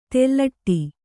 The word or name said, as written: ತೆಲ್ಲಟಿ